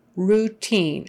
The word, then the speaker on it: routine: Routine. O-U in this case is pronounced oo. Routine. Routine. I-N-E is pronounced with a long E sound.